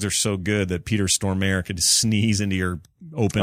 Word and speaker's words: Stormare: And it's pronounced Peter Stormare, by the way.